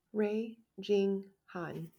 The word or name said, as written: Han Rae Jing